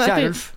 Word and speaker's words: Kjerulf: samfunnsdebattant som um, heter Anine Kirulf. Hun også hadde en Kjærulf. Kjærulf, sorry. Mm. Kjærulf, da. Men det staves Kjerulf.